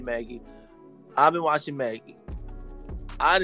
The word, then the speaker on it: Magi: Yeah, because I know they say Magi, because I always said Magi. – But then the show says Maggie. They associate Magi with, like, magic. – Yeah, I know. – Yeah. – I'm but not saying it's y'all not, wrong. I'm just saying it's Maggie.